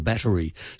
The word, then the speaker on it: battery: The Oxford definitely gives a three-syllable pronunciation, battery, so the little sound in the middle, we are meant to say, that's the correct pronunciation.